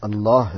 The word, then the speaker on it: اللہ: یہ تسبیح ذکر کے اندر جو اللہ ہے اس میں ہا کا تلفظ بہت واضح ہونا چاہیے ہر اللہ پر، چاہے آپ لا الہ الا اللہ کہیں یا الا اللہ کہیں یا اللہ اللہ کہیں یا اللہ کہیں.